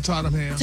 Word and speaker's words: Tottenham: Is that how you say it? – Uh-huh, on Tottenham.